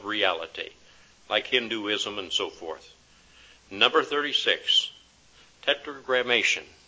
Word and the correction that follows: Tetragrammaton: Tetragrammaton is the correct pronunciation.